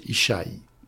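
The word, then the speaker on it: Ishaï (ישי): C'est un yod, un shin et un yod. En hébreu, cela se prononce Ishaï.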